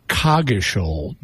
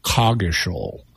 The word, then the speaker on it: Coggeshall: Also, there's an American English pronunciation, which is Coggeshall, different than Coggeshall, the British pronunciation.